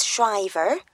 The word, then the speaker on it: Shriver: Yes, it's Mr. and Mrs. Shriver. – Can you spell that for me? – Yes, it's S-H-R-I-V-E-R.